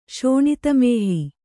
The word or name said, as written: ಶೋಣಿತಮೇಹಿ